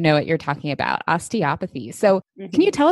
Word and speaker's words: osteopathy: And the other question I had was, you talked about your background in, I hope I say this right, osteopathy. Osteopathy. – Yeah, right. You can say it depending on what region you're in, you can say it either way.